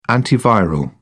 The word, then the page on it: antiviral: British English